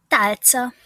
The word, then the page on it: talca: Hungarian